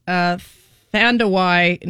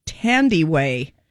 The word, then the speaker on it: Thandiwe: Thandawai Newton. For years, people misspelled her name as Thandi and let it go, but it's really pronounced Tandiway, T-H-A-N-D-I-W-E, Tandiway, Tandiway. The accent is on the D.